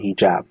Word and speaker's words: Hijab: You talked about the hijab. – Hijab, yeah.